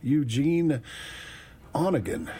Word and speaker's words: Eugene Onegin: Eugene Onegin. I was saying that wrong earlier. I think that's how they said it.